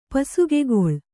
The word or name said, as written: ಪಸುಗೆಗೊಳ್